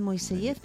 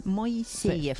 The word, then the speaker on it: Moiseyev: Los españoles dicen Moiseyev, pero los rusos dicen Moiseyev.